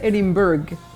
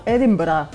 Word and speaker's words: Edinburgh: Tú sabes que no se dice Edinburgh, se dice como Edinburgh.